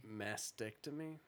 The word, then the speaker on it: mastectomy: How do you pronounce that word? – Doesn't really matter. – What was it? – Mastectomy. – Mastectomy. But there's a T in there. Is the T silent? No. It's after the S. It's M-A-S-T, right?